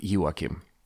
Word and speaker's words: Joakim: and I just go with Joakim. Soft, soft yay sound.